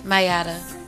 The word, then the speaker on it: Mayyadaa: Wait. How do you spell it? – I don't want to. – Please. – M-A-Y-Y-A-D-A-A. – Two Y's?